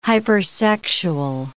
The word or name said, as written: hypersexual